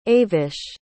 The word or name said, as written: avish